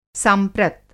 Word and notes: सांप्रत: Hindi

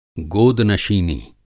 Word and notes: गोदनशीनी: Hindi

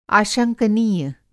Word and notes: आशंकनीय: Hindi

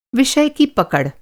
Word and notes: पकड़: پکڑ

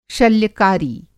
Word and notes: शल्य-कारी: Hindi

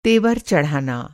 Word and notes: तेवर: تیور

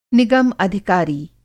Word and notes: निगम-अधिकारी: Hindi

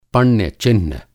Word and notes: पण्य-चिह्न: Hindi

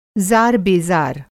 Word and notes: ज़ार-बेज़ार: Hindi

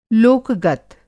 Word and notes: लोक-गत: Hindi

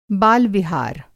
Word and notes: बाल-विहार: Hindi

